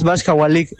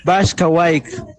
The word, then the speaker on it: Bashkawaik: Y la pronunciación también estuvo mal. No es Bashkawalik, es Bashkawaik.